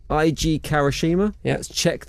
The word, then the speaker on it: Eiji Kawashima: Eiji Kawashima.